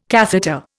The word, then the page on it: catheter: Indian